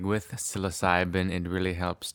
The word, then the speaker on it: psilocybin: And I'm saying psilocybe because I'm in Canada. And really, I've learned that like the age-old Canadian accent pronounces it as psilocybe. But for those who listen to a lot of American speakers, it's psilocybin.